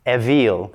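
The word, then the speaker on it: ewil: would be pronounced evil, which of course sounds rather like evil.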